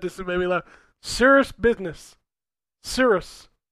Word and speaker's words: Cirrus: C-I-R-R-U-S. – Oh, like the clouds.